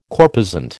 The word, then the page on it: corposant: American English